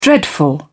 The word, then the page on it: dreadful: British English